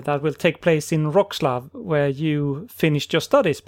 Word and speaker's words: Wrocław: It's it's Wroclaw and laugh, like laugh. Okay. – Oh. – So we pronounce it uh, a laugh in in the name of this uh, of the city. So Wrocław.